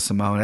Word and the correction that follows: Simon: That's clearly Simon.